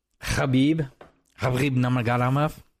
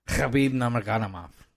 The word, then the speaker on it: Khabib Nurmagomedov: I mean, Khabib Nurmagomedov. – Because as long as you have some confidence. – It's K-H-I-B, whatever, however you spell it. But the K-H, Khabib Nurmagomedov.